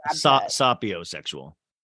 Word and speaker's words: sapiosexual: Like, Sophie, it's called sapiosexual. – Is it really? – No, it's sapio.